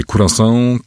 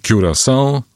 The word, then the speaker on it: Curaçao: Są bardzo różne wersje. Curaçao, Curaçao albo Curaçao.